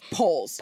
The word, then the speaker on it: Polis: Elected for governor in Colorado, Jared Polis. I hope I'm saying his name right. – I think it's Polis.